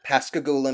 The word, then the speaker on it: Pascagoula: Now you said Pascagoula? – Pascagoula, Mississippi. Mm-hmm. – Pascagoula? – Can you spell that for me? – Pascagoula. – Pass the goulash.